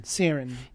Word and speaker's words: Cerin: Serin. – Yeah, her last name is spelled C-E-R-I-N.